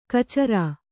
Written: ಖಚರ

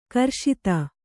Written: ಗ್ರಸ್ತ

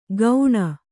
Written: ಗೌಣ

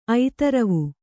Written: ಐತರವು

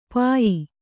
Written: ಪಾಯ

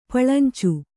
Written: ಪುಳಿಂಚು

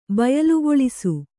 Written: ಬಯಲ್ಗೊಳಿಸು

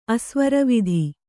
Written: ಅಸ್ವರವಿಧಿ

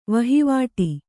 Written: ವಹಿವಾಟ್

